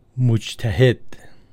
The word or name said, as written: Mujtahed